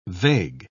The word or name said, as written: vague